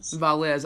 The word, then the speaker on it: Valles: Valles.